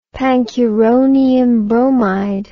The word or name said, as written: pancuronium bromide